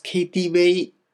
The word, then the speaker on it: KTV: 中国では B、 V のことをですね、ウェイと発音します。 KTV なんて言ったりします。